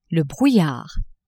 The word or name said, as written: brouillard